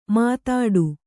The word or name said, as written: ಮುದುಡು